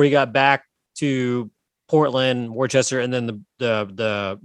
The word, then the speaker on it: Worcester: So it is pronounced Worcester, just so you know.